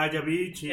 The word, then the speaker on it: Avicii: Ah, Avicii.